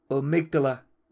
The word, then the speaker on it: amygdala: The amygdala.